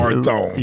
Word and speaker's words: Martone: Andrew Porter Malone. – Yes. Martone.